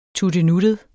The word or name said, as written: tuttenuttet